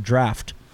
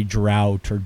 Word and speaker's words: draught: It's spelled D-R-A-U-G-H-T. And I hear people hem and haw trying to say drought. It's just draft, people. It's just draft. Pretend it's an F. It's just the English version.